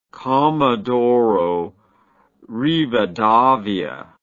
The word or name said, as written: comodoro rivadavia